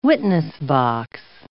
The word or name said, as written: witness-box